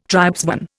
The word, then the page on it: tribesman: Indian